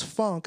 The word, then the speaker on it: phonk: So excuse me for calling it funk for, you know, now, but like I just love how you're like funk, which is spelled P-H-O-N-K, funk. – which could be pronounced as funk.